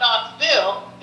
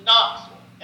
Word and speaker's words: Knoxville: Same as Knoxville. It's not Knoxville. It's Knoxville. Every Bille, a Southerner should be saying it like it's bull. V-U-L.